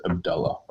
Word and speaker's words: Abdullah: His name, the way that you actually pronounce his name is Abdullah, by the way.